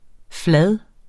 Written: flade

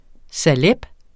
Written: salep

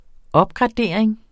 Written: opgradering

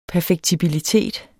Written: perfektibilitet

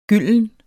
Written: gylden

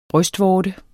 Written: brystvorte

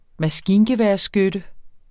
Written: maskingeværskytte

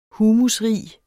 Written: humusrig

